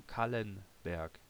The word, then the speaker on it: Kallenberg: I will tell no Kallenberg, LL, double L. No, and not in Spanish, Kallenberg.